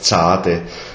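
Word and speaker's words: Tsade: Dieser Buchstabe heißt Zade und wird eben als Z ausgesprochen. Geschrieben wird aber selbst, also wenn man es ins Deutsche übertragt, schreibt man es eben mit TS, Zade.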